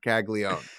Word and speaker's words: Caglianone: But what I want you to do is when you see that A-N, I want you to flip it around. I want you to say, nah, okay? Because you're not going to pronounce it. It's just Caglione.